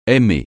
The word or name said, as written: aimer